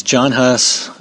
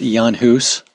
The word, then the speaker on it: Jan Hus: Okay, some people know him as John Hus. In Czech, it's pronounced Jan Hus, which means...